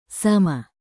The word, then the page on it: sama: indonesian